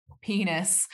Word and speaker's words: Pinus: Pinus, which actually includes more than penis. – It literally is like penis with an I is how it's spelled.